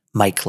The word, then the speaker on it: Myk: The handles are at Modern Home and then at Mike Likes. And my name is spelled M-Y-K. So it's M-Y-K-L-I-K-E-S.